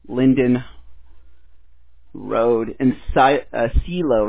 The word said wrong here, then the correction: Clio: right? S-L-I-O, Michigan? – Clio. – No, Clio. It's C-L-I-O. – C-L-I-O 48420, correct? I mispronounced that.